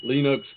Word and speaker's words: Linux: Rhymes with Spinux. Linux, baby.